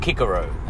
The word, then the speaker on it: Cicero: Cicero, you know, who in his time might have been called Cicero, they tell me. They didn't have a soft C.